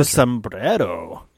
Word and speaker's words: sombrero: Did I pronounce that correctly? – Yeah. – Sombrero. – Why'd you look? Yeah. – Yeah. – Sombrero. – Sombrero. – You really have to roll the R's.